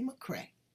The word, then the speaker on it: McCrea: We're going to visit with Lindsey McCrea.